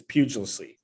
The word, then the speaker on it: Pugliese: Did you pronounce his name like that on purpose? – Pugliese. I've been saying it, yeah. And I'm not deviating. It's Pugliese.